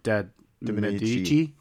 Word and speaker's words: Medici: De Medici.